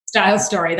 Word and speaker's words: Style Story: So the website where we can find the curated products is Style Story. Am I saying that right? – Style Story, that's right.